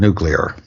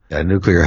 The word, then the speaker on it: nuclear: Nuclear has to do with the nucleus of atoms, and it's not nuclear. Most people seem to find pronouncing nuclear very difficult, and the vast majority of time when you ask people who are not specialists or experts, it'll come out nuclear. So much so that some dictionaries have now accepted nuclear as an alternative pronunciation.